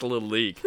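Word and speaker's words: Lalique: It's pronounced Lalik.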